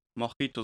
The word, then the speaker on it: Mojito: Mojito, so wird das echt ausgesprochen. Mojito.